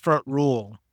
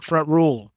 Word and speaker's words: Front Royal: We are headed out to Front Royal to play probably a course that we've played more than most. – believe it's pronounced Front Rule if you're out there. Front Rule. – Front Rule.